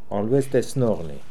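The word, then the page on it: Olooestesnokhnee: Russian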